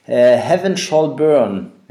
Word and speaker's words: Heaven Shall Burn: Wir haben hier noch, jetzt muss ich es mal, Heaven Shall Burn. Ja.